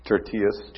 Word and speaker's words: Tertius: Tertius.